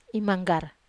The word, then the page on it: manggar: wamesa